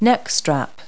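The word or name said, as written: neck strap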